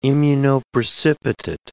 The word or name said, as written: immunoprecipitate